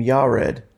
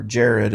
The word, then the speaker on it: Yared: And then we have this guy named Yared, or Jared as it's pronounced in English.